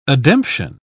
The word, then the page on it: ademption: British English